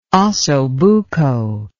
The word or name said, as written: ossobuco